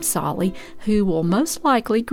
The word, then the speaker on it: Tsali: That is spelled T-S-A-L-I, Solly.